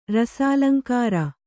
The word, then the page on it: ರಸಾಲಂಕಾರ: Kannada